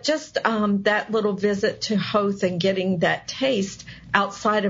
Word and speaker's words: Howth: And the little town of Howth, it's H-O-W-T-H, but it rhymes with growth. So as an American, I want to say Howth, but it's Howth.